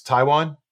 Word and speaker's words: Taijuan: I'm excited to see Taiwan Walker. I think he could be a really interesting addition to the rotation. – I think I just pronounced it Taijuan. It's Taiwan.